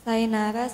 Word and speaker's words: Sainara: Sainara Sofia Naftalinda. – Oh, Sainara bacanya ya, saya salah.